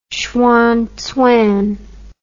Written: hsuan tsang

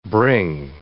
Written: bring

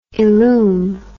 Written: illum

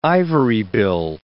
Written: ivorybill

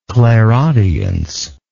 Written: clairaudiently

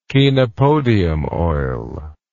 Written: chenopodium oil

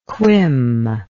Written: quim

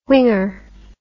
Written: whinger

